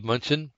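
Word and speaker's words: Mnuchin: So I don't know if it's if it's the if you do it like the Greek with pneumonia, it'd be Nunchin, but I think they pronounce it munchin, and the end is silent.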